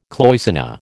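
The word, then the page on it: cloisonné: American English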